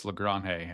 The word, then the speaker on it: Lagrange: I think he's generated some buzz, and so has Carlos Lagrange with the Yankees, who, I mean, he's just His name is pronounced Carlos Lagrange after a lot of confusion in spring training. I know Josh was on top of this for us last year. He was saying it's pronounced Carlos Lagrange. And then in spring training, I guess someone had asked him, is Lagrange okay? And it maybe seemed like he was like, yeah, that's fine. And then clarified the pronunciation is Lagrange.